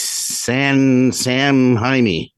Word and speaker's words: Samhain: Well, didn't you just mispronounce it again? – Yeah, maybe. – Yeah, I think you did. No, because I always butchered that too, and then your niece politely corrected us. Yes, she did. She would know. – Yes. – It's Sam Jaime.